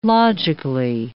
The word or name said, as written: logically